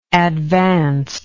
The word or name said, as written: advanced